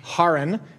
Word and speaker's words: Haran: Found out it's properly pronounced Haran, which is very hard for me to say, but I'm going to try.